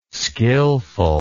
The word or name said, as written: skilful